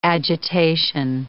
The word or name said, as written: agitation